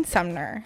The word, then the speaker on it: Sumner: I am joined with a very special guest today, Damon Sumner.